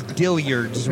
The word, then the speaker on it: Dillard's: This is Dillard's, rhymes with billiards. Gotta roll your R, Dillard's.